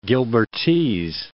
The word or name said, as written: Gilbertese